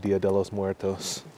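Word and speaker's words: Día de los Muertos: I wonder how, like in Mexico, they have Dia de los Muertos. – Dude. – Did I nail that pronunciation? – Heck yeah.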